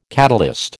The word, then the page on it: catalyst: American English